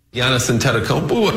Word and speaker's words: Giannis Antetokounmpo: Giannis Antetokounmpo. – The NBA player Giannis Antetokounmpo caught my eye.